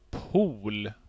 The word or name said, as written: pol